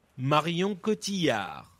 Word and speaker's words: Marion Cotillard: Marion Cotillard.